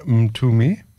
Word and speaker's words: Ntumi: And I think so by the name Ntumi. That's how you say it.